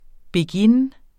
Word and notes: beguine: [ beˈginː ]

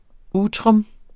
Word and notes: utrum: ˈuːtʁɔm

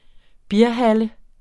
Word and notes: bierhalle: [ ˈbiːɐ̯ˌhalə ]; [ -ˌhɑlə ]

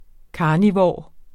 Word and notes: carnivor: ˈkɑːniˌvɒˀ; ˈkɑːniˌvoˀɐ̯

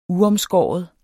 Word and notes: uomskåret: ˈuʌmˌsgɒˀɒð

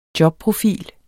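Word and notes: jobprofil: Danish

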